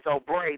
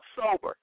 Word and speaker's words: Sobre: That's, you would think it would say Sobre the way it's spelled, because his name is S-O-B-R-E. That's how you spell it. And I used to say Sobre, but they said it's called Sober.